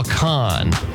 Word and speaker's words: Kahan: Do you have any Noah Kahan? I'm not sure if I'm pronouncing his name right. – Oh, Noah Kahan. – Like con artist.